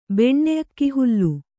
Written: ಬೆಣ್ಣೆಅಕ್ಕಿಹುಲ್ಲು

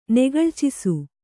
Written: ನೆಗಳ್ಚಿಸು

